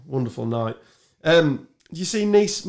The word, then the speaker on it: Nice: there's a tweet that's quite funny because obviously Nice is pronounced Nice.